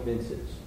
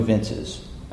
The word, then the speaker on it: vinces: In hoc signo vinces. – Okay, except in classical Latin, the V is pronounced as a W.